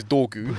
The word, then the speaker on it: Dorgu: I'm calling him Drogon. – Man said Drogon. – His name is Dorgu.